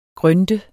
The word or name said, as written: GRYNT